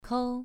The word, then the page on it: kou: 2